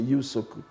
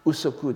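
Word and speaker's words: Yusoku (有職): la plupart du temps, je, la, la, prononciation la plus, la plus standardisée, enfin, si l'on peut dire, c'est Yusoku. Usoku est aussi possible.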